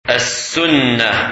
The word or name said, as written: Sunnah